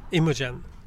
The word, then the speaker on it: Imogen: Med reservation för uttalet av hennes förnamn. – Jag tror det är Imogen. – Imogen Cunningham. Ja.